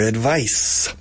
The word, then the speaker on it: advice: But in any case, don't expect Maria to follow your advice. Advice sempre singular. Advice, advice, advice, advice. Con C-E al final. Como sustantivo, como verbo con S-E. Advise. And the pronunciation changes.